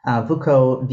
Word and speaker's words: Vucko: Lex, it's spelled L-E-K-S, Vucko, V-U-C-K-O.